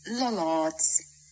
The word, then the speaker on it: Lollards: Lollards.